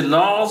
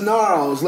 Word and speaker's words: Gnarls: Listen, that G is silent. – It's just Gnarls. Like, it ain't Janarls. It ain't with a G.